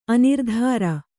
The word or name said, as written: ಅನಿರ್ಧಾರ